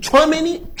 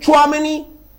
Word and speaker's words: Tchouaméni: Aurélien Tchouaméni, Bamileke, del oeste de Camerún, su padre los dos, Bamileke, de Camerún, y Tchouaméni, escuchar bien el apellido, que lo pronuncio como se pronuncia en mi tierra, Aurélien Tchouaméni, así se pronuncia,